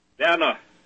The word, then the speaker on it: Werner: Werner. The W is pronounced like a V in English.